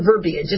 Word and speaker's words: verbiage: But with the way the verbiage, is it verbiage or verbiage? It's spelled verbiage, but I don't think it's supposed to pronounce the I.